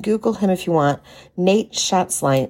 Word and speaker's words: Nate Schatzline: There's a guy, Representative Nate Schatzline is his name.